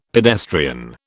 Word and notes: pedestrian: British English

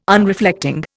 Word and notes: Unreflecting: Indian

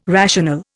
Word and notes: rational: Indian

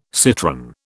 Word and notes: citron: British English

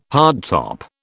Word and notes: hardtop: British English

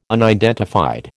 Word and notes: unidentified: American English

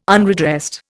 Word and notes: unredressed: Indian

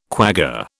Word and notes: quagga: British English

